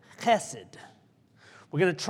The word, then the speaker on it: chesed: There's a sound at the beginning, but it's chesed. That was better.